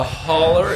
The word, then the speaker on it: holler: So a holler is the local pronunciation of a hollow.